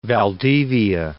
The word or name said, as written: Valdivia